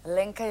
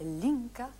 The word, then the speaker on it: Lenka: Víte, Bolku, že Francouzi někdy vyslovovali Lenka jako Linka? – Jako Linka? – Linka, ano, Linka.